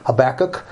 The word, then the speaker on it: Habakkuk: Habakkuk, um, or as they say in America, Habakkuk. I think that's the right way to say it. Um, the book of Habakkuk.